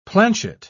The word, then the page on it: planchet: British English